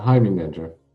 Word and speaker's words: Heimerdinger: Today we're going to be talking with how do you actually say your username? Is it like Heimerdinger? – Yeah, Heimerdinger.